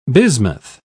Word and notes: bismuth: British English

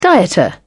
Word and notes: dieter: British English

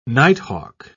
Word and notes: nighthawk: British English

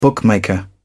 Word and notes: bookmaker: British English

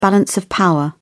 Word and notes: balance of power: British English

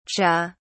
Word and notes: cha: British English

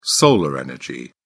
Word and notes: solar energy: British English

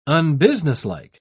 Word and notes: unbusinesslike: British English

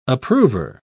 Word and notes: approver: British English

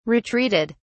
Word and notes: retreated: British English

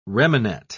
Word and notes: remanet: British English

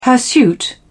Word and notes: hirsute: British English

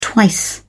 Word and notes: twice: British English

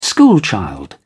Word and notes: schoolchild: British English

